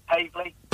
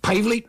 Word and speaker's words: Paveley: Yeah, you just got the surname pronounced wrong. We pronounce it Pavley. – Pavley? – Pavley.